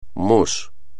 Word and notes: mus: espagnol

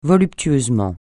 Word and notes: voluptueusement: francais

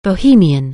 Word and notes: bohemian: anglais

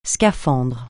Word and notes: scaphandre: francais

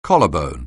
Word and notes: collarbone: anglais

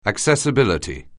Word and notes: accessibility: anglais

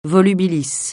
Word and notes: volubilis: francais

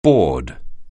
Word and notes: board: anglais

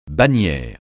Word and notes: bannière: francais

